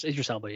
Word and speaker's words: Idris Elba: Uh, is it Idris? Idris Elba? – Yeah. Yes, Idris Elba.